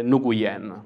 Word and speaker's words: Nguyen: quindi il mio cognome in realtà ha varie pronunce, possiamo dire che si può pronunciare Nguyen.